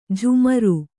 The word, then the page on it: ಜಾಮಾರು: kannada